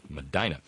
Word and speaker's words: Medina: Yes, I said it right. Medina.